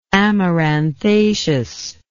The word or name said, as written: amaranthaceous